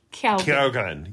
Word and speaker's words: Keoghan: Barry Keoghan.